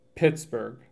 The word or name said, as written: Pittsburg